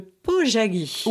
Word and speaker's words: pojagi: Oui, alors vous le dites bien déjà. Alors c'est vrai que le pojagi, euh, je l'ai découvert à travers un livre donc, sur lequel était écrit pojagi. P-O-J-A-G-I, ce qui n'est pas du tout notre prononciation habituelle.